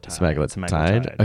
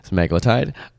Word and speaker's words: semaglutide: semaglutide. Is that how you say it? – Semaglutide. – Okay. Semaglutide. I was not looking at my note. I mispronounced it.